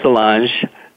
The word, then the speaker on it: Solange: Solange? – Close, Solange.